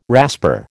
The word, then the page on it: rasper: American English